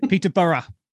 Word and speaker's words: Peterborough: Peterborough. That's how it's pronounced here.